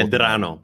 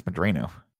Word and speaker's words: Medrano: I think it's Medrano. – It's Medrano. It's spelled the same, what we're saying, but you're just pronouncing it. – They were pronouncing it with the anglified pronunciation.